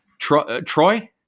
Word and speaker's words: Troyes: Troyes.